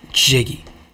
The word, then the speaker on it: Jiggy: A lot of people say Ziggy. Not Ziggy. It's not pronounced Ziggy like Ziggy Marley. Mm-hmm. It's pronounced Jiggy.